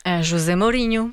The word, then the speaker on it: José Mourinho: José Mourinho.